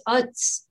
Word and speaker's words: Quds: And then in Arabic, it's Uds, but it's hard to say. So it's spelled Q-U-D, like D-S, and means Jerusalem, actually.